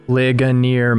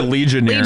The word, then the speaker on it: Ligonier: So you have, like, you know, the John MacArthur people and the Ligonier people, and you have Ligonier. – Ligonier. – I tried to say it right this time. – He calls it Legionnaire. – Legionnaire. – I usually say Legionnaire.